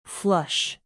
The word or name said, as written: flush